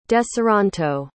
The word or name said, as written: deseronto